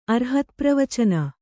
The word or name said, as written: ಅರ್ಹತ್ಪ್ರವಚನ